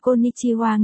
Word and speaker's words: Konnichiwa: Konnichiwa, hai, phát âm là Konnichiwa nghĩa là xin chào, sinh nhật vui vẻ, âm thanh phát ra là giọng của một phụ nữ, là tiếng phổ thông cực kỳ chuẩn.